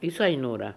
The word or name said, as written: Issi Noora